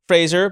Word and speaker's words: Fraser: I, Dominique Fraser, but say your name the last name you'd say it in French.